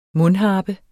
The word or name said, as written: mundharpe